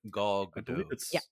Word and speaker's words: Gal Gadot: How do you pronounce her name? Is it Gal Gadot? – Gal Gadot. – I think she said it out loud as Gadot.